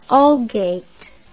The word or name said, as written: algate